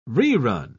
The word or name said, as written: rerun